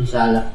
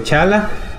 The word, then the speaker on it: T'Challa: ¿Así se pronuncia? – Sí, T'Challa. – Ah, ok, T'Challa.